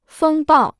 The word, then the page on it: 风暴: fēng bào